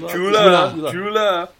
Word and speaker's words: Chula: Yeah, if he's going to go up against someone like Chula, is it Chula? Is that how you pronounce it? – Yeah, Chula.